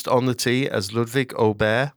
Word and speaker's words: Åberg: It is Åberg. They're giving it the hard G.